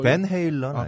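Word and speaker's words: Van Halen: 벤 헤일런. 아, 벤 헤일런이라고 읽어도 되나요? 좀 전에 지금 트라이올런 하지 말아요. 아, 그래요? – 벤, 벤 네. 헤일런. – 아니, 우리, 우리 쪽 서부 쪽에서는 좀 그렇게 읽어요. 자, 영웅, 은하 영웅 전설에 대한 철학적 해석이나 영웅주의.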